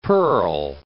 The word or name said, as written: pearl